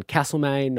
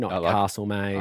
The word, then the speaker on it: Castlemaine: Notice the pronunciation on Castlemaine, Castlemaine.